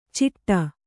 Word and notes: ಚಿಟ್ಟ: ciṭṭa

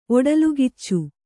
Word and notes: ಒಡಲ್ಗಿಚ್ಚು: oḍalgiccu

